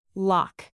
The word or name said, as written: lock